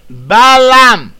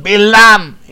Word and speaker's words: Balaam: Balaam, or Bilam. In Hebrew it is more, the pronunciation Bilam is more acceptable.